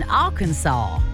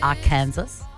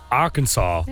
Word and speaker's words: Arkansas: He was spotted in the crowd at Ella Langley's concert in Arkansas. Do you know that's how you pronounce it? Not Arkansas. – Arkansas. – Yeah, I didn't know that. Because it's like Saw.